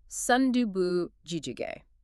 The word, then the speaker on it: sundubu jjigae: It is pronounced sundubu jjigae. – It has a very specific rhythm to it, yep. – Right? Sundubu refers to the soft tofu itself, and jjigae is the stew.